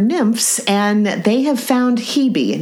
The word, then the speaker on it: Hebe: Now, I want to pause for a second because I was able to verify, yes, it is pronounced Hebe. Because I looked it up and I saw, you know, Hebe, and I was hoping that was it. And I asked Nathan, and he said, yeah, I think that's possibly Hebe, like Phoebe.